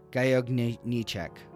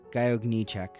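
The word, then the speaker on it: Gajowniczek: And one of them, Franciszek Gajogniczek, is how you say it. I've been practicing that for a while, Thomas. Well, Franciszek Gajogniczek.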